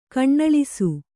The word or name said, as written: ಕಣ್ಣಳಿಸು